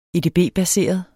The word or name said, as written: edb-baseret